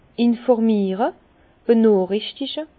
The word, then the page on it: informiere, benoochrichtige: Bas-Rhin; Herrlisheim